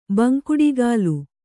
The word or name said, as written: ಬಂಕುಡಿಗಾಲು